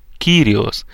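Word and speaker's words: кириос (κύριος): Слово «Господь» на греческом языке произносится как «кириос» и означает «господин», «властелин».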